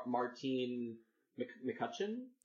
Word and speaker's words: Martine McCutcheon: Martine McCutcheon? – Yeah.